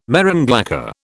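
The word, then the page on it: marron glacé: British English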